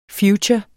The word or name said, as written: future